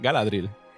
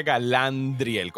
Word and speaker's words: Galadriel: Galadriel. Galadriel. Galadriel. Okay. – Yo le eh. decía Galandriel con una N que yo no sé de dónde carajo me la saqué.